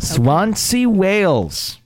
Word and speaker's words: Swansea: Okay. Swansea, Wales. – That's right.